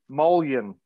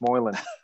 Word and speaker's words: Moylan: Moyane? Is that your last name? Is that how you say it? – Moylan. Peter Moylan. Yeah, yeah. The L is not silent. It's Moylan.